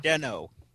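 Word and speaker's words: Den-O: No, Deno. – Did you say Deneb? – Oh, how I wish it was Kamen Rider Deneb. But yes, Deno, Deneb, whatever.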